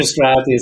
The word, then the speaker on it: Strathy: You got it absolutely right, but I'm not Chief Justice. I'm not Chief Justice anymore. – Chief Justice. – Or Mr. Strathy is fine, but whatever. You got the pronunciation. Parfait.